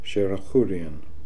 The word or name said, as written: Sherakhoorian